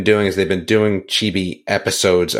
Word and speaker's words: Chibi: Is it Chibi? – Chibi. – C-H-I-B-I, like, whatever that is.